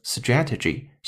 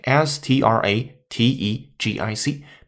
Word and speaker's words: strategic: 它的发音发生了变化，名词 strategy，形容词 strategic，strategic 这样的一个声音，拼写 s t r a t e g i c，表示战略上的。